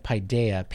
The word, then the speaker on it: Paideia: P-A-I-D-E-I-A. Paideia. – Do they have a mission statement? – Let's see if I can find that. I was going to actually look up the definition of Paideia. P-A-I-D-E-I-A.